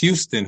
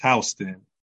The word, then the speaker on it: Houston: A lot of people mispronounce it as Houston who are not familiar, but it's Houston.